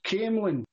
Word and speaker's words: Camelon: It's pronounced Camelon. But it's spelled Camelon, Camelon.